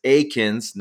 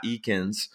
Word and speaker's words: Eakins: It's Eakins, not Eakins.